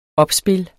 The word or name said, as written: opspil